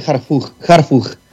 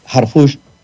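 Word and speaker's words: Harfuch: Ya investigué lo más que pude y el origen del apellido de Jarfush no es para pronunciar las últimas letras como J, no es de alemán ni de ningún otro origen que se pronunciara como J, la H.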